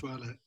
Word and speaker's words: Masoala: Um, it, am I pronouncing it Masoala? Masoala Forest. Masoala. Yes, very good.